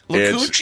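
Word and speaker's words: Lacoochee: Is that how you say it? – La Cucci? It's L-A-C-O-O-C-H-E-E.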